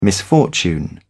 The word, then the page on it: misfortune: British English